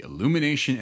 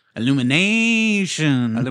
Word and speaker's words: Illumination: we were supposed to get the Illumination Entertainment version. – No. You said that wrong. – Oh. – Illumination.